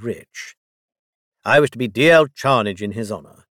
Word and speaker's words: Dalziel: "Pronounced D-L, spelt D-A-L-Z-I-E-L. It's a Scottish surname.